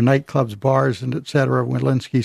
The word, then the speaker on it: Walensky: The CDC director now, Rochelle Walensky, is that how she pronounces his name? – Mm-hmm.